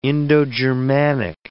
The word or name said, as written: Indo-Germanic